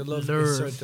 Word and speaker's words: Louvre: Is that how you say it? – Jean-Michel I don't think Jarre. That's how you say it. The Louvre.